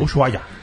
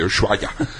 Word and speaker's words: Ushuaia: Fala aí pra mim, como é que fala mesmo? – Ushuaia. – Isso aí, Ushuaia.